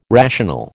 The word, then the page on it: rational: American English